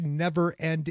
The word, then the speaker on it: NVRND: is the way Never End is spelt. It's spelt N-V-R-N-D.